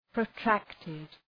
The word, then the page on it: protracted: prəʋ’træktıd